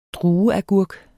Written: drueagurk

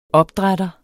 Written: opdrætter

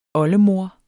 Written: oldemor

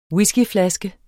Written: whiskyflaske

